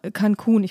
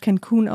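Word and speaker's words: Cancún: Die Frau von Ted Cruz hat dann SMS an Freunde geschrieben, ob sie nicht nach Cancun, ich glaube, ich habe es gerade sehr amerikanisch Cancun ausgesprochen, ähm, nach Cancun fliegen wollen.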